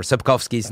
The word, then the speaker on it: Sapkowski: Andre or whatever Sapkowski's name is. – Yeah. Oh, that's good pronunciation. – I try. Right, W's or V's sometimes, I think, over there.